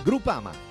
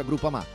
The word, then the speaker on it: Groupama: Biancorosso Groupama, ah no, si pronuncia Groupama, di Paolo Felicetti.